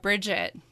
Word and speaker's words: Briget: It is a very B-R-I-G-E-T, so I thought there might be kind of a French pronunciation. – Yeah, no French pronunciation. – But there's no D in it either, so it's not Bridget, it's Bridget. – Yep. – That's kind of the way we say it.